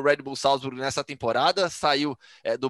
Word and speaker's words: Salzburg: Tem que falar, mas tem que falar com, como a pronúncia na língua original. – Ah, não. – Red é Bull Salzburg. Salzburg. O, o mais correto é Salzburg.